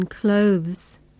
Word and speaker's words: clothes: Notice that in spoken English, we usually pronounce the th sound in clothes as s.